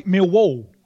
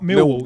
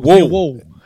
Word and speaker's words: Millwall: I think you're supposed to say Millwall, aren't you? Not Millwall. Millwall. Which is quite hard to do unless you're from the area. It's quite hard to put the stress on a second syllable, isn't it?